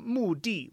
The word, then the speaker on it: 目的: And also in the word "mu di," where it's pronounced di, mu di," that means purpose or, you know, like.